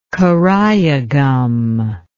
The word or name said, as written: karaya gum